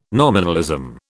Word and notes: nominalism: British English

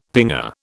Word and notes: pinger: British English

